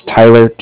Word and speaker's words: Tyler: Again, my name is Tyler, T-Y-L-E-R, and I'm calling with Cardiff. It's spelled C-A-R-D-I-F-F, as in Frank Frank.